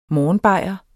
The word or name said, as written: morgenbajer